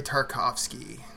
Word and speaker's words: Tarkovsky: Andrei Tarkovsky.